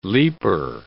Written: leaper

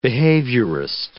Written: behaviorist

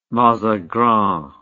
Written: mazagran

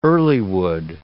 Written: earlywood

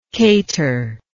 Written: cateringly

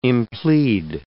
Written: implead

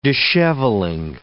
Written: dishevelling

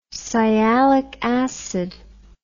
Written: sialic acid

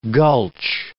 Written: gulch

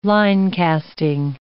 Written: linecasting

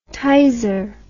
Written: Tizer

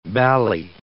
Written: bally

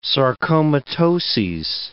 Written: sarcomatoses

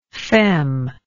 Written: feme

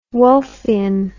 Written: wolffian

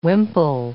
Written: wimple

